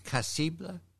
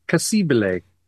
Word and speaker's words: Cassibile: We begin with the Italians at Cassibile. How do you say that? – Cassibile.